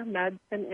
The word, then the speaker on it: Madson: So I'm everywhere on Facebook under Jennifer, J-E-N-I-F-E-R, Madson, M-A-D-S-O-N.